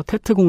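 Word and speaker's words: Tết: 어, 베트남에서 구정을 떼이라고 하기 때문에 음. 떼 공세라고도 불리고 이를 미국식으로 발음해서 테트 공세라고도 불립니다.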